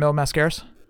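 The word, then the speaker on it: Mil Máscaras: Possibly a third, I guess, would be Mil Mascaris. I guess everybody pronounces it different. – I think you had it right the first time, Mil Mascaris.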